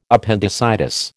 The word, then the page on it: appendicitis: American English